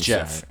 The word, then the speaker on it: Geoff: I'm going to say Joff this entire time. – It's Geoff. – I know it is, but how it's spelled, it's Joff. So...